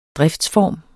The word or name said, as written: driftsform